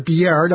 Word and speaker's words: Biard: Nexstar Chief Executive Michael Biard, I think that's how you pronounce it, it's spelled B-I-A-R-D, I think Biard is the only way to pronounce that,